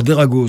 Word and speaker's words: Drago: Alors, Derago, ça s'écrit D.R.A.G.O.